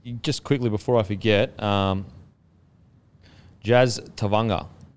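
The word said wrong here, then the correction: Tevaga: It is actually pronounced. I messaged him. I was like, sorry, bro. I think I've been saying your name wrong. He's like, I don't give a fuck. so I was like, what are you messaging me for? So Tevanga. Tevanga. So T-E-VUN-R. Tevanga. So Jazz Tevanga.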